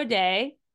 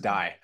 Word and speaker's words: Dai: And I know sometimes it's pronounced Day, but sometimes it's also pronounced Die. So we'll go with the Welsh pronunciation. But no, you're correct. It is pronounced both ways, but I usually pronounce it my Welsh form.